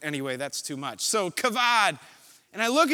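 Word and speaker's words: kavod (כָּבוֹד): And so I took my Hebrew lexicons off the shelf and I opened up and I'm with Hunter and we're arguing over how to pronounce this word. And we landed on the way I wanted to pronounce it, which was kavod.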